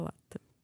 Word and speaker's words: латте: Как запомнить слово «латте»? Все рекламы сняты, скорее бежим за латте.